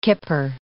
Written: kipper

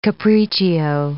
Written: capriccio

